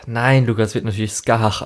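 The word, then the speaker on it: Scáthach: Und es ist natürlich auch hier irische Mythologie und darum, das wird halt Skahach ausgesprochen.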